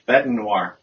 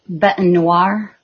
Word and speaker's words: bête noire: Bête noire. – Bête noire. – It's pronounced B-E-T apostrophe N-W-A-R, if that was phonetically spelled, and it's accurately spelled. It's a French term, B-E-T-E-N-O-I-R-E.